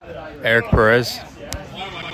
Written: Eric Perez

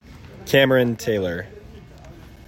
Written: Cameron Taylor

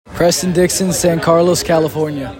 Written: Preston Dixon

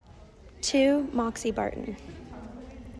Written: Moxie Barton

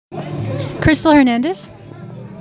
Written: Crystal Hernandez

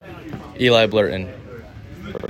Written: Eli Blurton

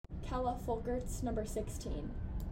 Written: Kella Folkerts

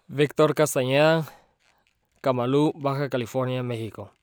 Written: Victor Castaneda

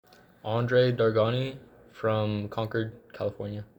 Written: Andre Dargani

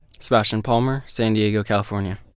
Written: Sebastian Palmer

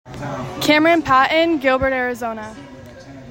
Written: Camryn Patten

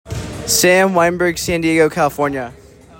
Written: Sam Weinberg

